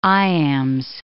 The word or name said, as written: iambs